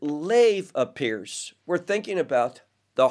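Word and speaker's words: lev (לב): One is the word lev, and it's spelled lamed vet.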